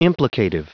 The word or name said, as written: implicative